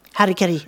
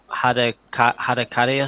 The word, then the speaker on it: Harikiri: It's Japanese. And also, I originally recorded it in the wrong way. I said Harakari or something.